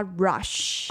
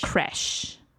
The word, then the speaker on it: crash: My Cadillac was completely crushed in the car rush. – Crash.